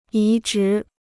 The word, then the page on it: 移植: yí zhí